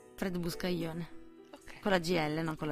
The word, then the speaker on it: Buscaglione: Fred Buscaglione.